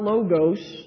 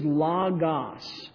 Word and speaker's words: logos: this is not logos, this is logos. The O is a short O in the word pot, or whatever other word we have there. So it's ah.